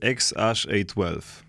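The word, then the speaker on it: X Æ A-12: E, znalazłem, że ten syn, jego z tym dziwnym imieniem, to jest poprawna wymowa, to jest X-Ash-A12. Tak to się wymawia.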